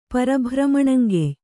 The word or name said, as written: ಪರಭ್ರಮಣಂಗೆಯ್